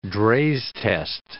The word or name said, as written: Draize test